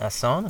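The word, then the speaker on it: asson: And Urban Haas, author of Chasing the Asan. Am I saying that right? – Yeah, that's fine.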